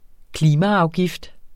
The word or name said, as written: klimaafgift